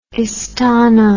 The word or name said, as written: istana